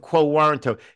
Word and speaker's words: quo warranto: A writ of quo warranto. It's spelled like the word warrant with an O at the end.